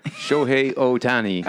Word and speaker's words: Shohei Ohtani: Oh, Shohei Ohtani.